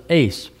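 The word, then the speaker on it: ACE (εἰς): If you want to put a note here, it's signified by our English letters EIS, or we might pronounce it ACE.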